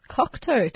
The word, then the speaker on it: Cocteau: This is Cherry Coloured Funk by the Kuketa Twins. And you're listening to 3CR. Now, my producers just told me that it's actually pronounced Cocteau Twins.